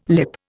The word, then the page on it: lip: Indian